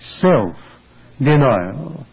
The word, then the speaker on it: self-denial: Time and again, the Savior in the Gospel tells us that we are as good followers of Himself as we practice self-denial, with a stress on the first part of that expression, self-denial.